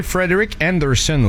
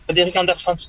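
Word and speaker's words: Frederik Andersen: Chez les Hurricanes de Caroline, s'entêter à utiliser Frederick Anderson ou Frédéric Anderson, dépendamment de comment vous voulez le prononcer. – Frédéric Anderson.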